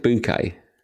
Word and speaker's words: Bucket: Is that Hyacinth Bouquet? Yeah, but it's spelled bucket, you see.